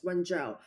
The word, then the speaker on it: Wenzhou: So it's Wenzhou.